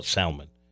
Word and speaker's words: salmon: In my family, we call it salmon. – Oh, but, I didn't know you pronounced the L. – Yeah, well, I mean, that's the standard.